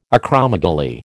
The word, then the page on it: acromegaly: American English